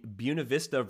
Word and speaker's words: Buena Vista: And I did double check this. This is the more ang- anglicized pronunciation of Buena Vista, just like Buena Vista College. It's not the Spanish pronunciation. So, uh, Southern Virginia and Buena Vista in Storm Lake, Iowa, we can stick with one pronunciation there.